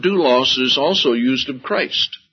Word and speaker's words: doulos: D-O-U, and then the next letter looks like an upside-down Y. That is an L or a lambda. O-S, and that's pronounced doulos.